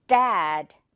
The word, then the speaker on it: dad: 먼저 첫 번째 단어, 아빠라는 단어인데 이거 발음할 때 그냥 한글의 디귿 생각하면 dead 이렇게 평범하게 나오죠? 자, 영어에서는 dad. 지금 사운드 다르죠? 이 차이의 포인트가 뭐다? 혀로 여러분 입천장에 도장을 꾹 누르듯이 꾹 누르는 그 압력이 포인트다라는 거죠.